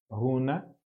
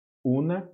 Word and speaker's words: Huna: Da mesma maneira que a palavra runa, H-U-N-A, runa, una ou runa, é assim que se pronuncia, significa o segredo.